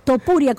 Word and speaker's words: Topuria: Tupuria, como digo yo. ¿Algún padre quiere que el niño sea Tupuria? Topuria, ¿cómo se llama el individuo? Siempre lo digo mal.